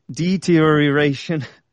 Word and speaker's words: deterioration: Imagine the word interior, but deterioration.